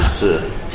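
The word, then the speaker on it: si (死): Because number 4 is pronounced si, and to die is also si.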